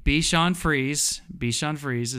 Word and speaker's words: Bichon Frise: Bichon Frise is, I think, is what it's pronounced.